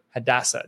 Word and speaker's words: Hadassah: They have one daughter Hadassah.